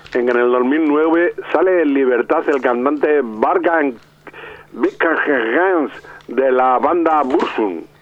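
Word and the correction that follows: Varg Vikernes: Lo ha dicho usted, lo ha pronunciado de una manera muy rara, porque él se llama Bark Bickernes y era un noruego. – Bueno, pues, Bark Bickernes.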